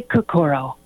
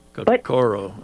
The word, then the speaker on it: Kokoro: I say Kokoro. – Kokoro.